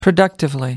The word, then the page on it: productively: American English